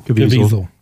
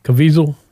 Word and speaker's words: Caviezel: Well, it's from a speech from, I can't pronounce the guy, Jim Caviezel. – Caviezel? – Yeah.